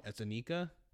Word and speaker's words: Anika: I thought it was fantastic, mainly for Anika. Is that how you say it? – Yeah.